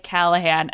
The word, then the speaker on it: Callaghan: Yeah, so I spell mine with the G in it. – Oh, yeah. – And do you know how many people are like, so how do you say that? I'm like, you say it like Callahan.